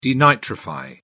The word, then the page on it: denitrify: British English